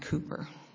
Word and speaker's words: Cowper: In the 18th century, a poet named William Cowper, well, it's probably pronounced Cooper, but it's spelled Cowper, wrote a poem called "God Moves in Mysterious Ways."